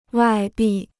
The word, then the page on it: 外币: wài bì